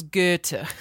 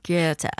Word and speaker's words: Goethe: So a very real person who claims to have seen their own doppelganger is Goethe. – Goethe.